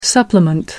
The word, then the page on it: supplement: American English